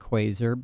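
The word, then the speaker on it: Quaser: We opened with the band Quasar out of Japan, and I'm pronouncing it Quasar because it looks like Quasar, but it's spelled E-R instead of A-R.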